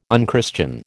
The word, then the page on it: unchristian: American English